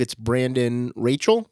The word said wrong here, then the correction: Rochelle: Is that correct? Am I saying that? – Rochelle. – So Rochelle, yeah.